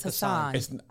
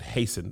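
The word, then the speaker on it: Hasan: I think he pronounced it Hasten, though. He said he let people call him Hassan, but let me YouTube that. – YouTube it. – How does Hasten Minhaj pronounce his? I think I watched it in his special. And he was like, it's actually Hasten.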